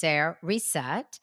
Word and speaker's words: Cereset: it's spelled C-E-R-E-S-E-T, just Sarasette.